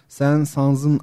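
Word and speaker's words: Saint-Saëns: Besteci Sen Sans'ın adı da e, Fransız bir arkadaşımdan öğrendiğim kadarıyla sondaki S ile beraber okunuyor. Yani sen şeklinde.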